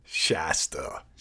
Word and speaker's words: Shasta: How do you say that? – Shasta. – Shasta? – Yeah.